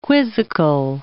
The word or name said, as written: quizzical